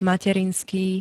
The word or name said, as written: materinský